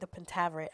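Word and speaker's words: The Pentaverate: I think I'm saying that right, The Pentaveret. I always, every time I watch the intro, I'm like, oh, that's how you say it. So if I'm saying it wrong, my bad.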